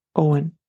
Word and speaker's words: Eoin: Next is Owen, E-O-I-N, which is said to be the actual Gaelic form of the name John, meaning God is gracious. It is a popular name in Ireland since Christian times at least, and could be associated with strength and kindness. And again, it's pronounced Owen. Saoirse, that's S-A-O-I-R-S-E.